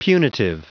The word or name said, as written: punitive